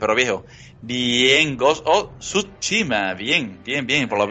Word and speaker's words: Tsushima: Ahí está, es Tsushima. Ajá. La T está ahí en medio. Mucha gente se la zarta y dice el Ghost of Tsushima, ¿no? Tsushima. Está mal pronunciado.